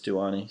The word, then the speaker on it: Stuani: I was just going to say, I want to pay attention to, I can't pronounce his name, Luke Stuani or something like that, the Uruguayan. – Yeah, it's just Stuani. Stuani, yeah.